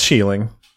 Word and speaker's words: sheiling: You don't even know if you're pronouncing it right either. – No, it's sheiling. – Okay, all right. – Because also spelled sheiling, sheiling, and sheiling. A-E-I, or E-A-I...